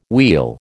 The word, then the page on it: wheel: American English